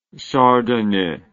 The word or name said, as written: chardonnet